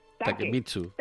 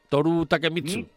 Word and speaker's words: Takemitsu: Takemitsu. – Toru Takemitsu.